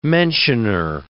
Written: mentioner

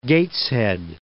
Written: Gateshead

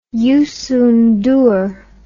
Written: Youssou N'Dour

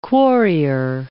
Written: quarrier

